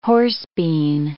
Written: horsebean

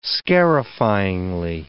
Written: scarifyingly

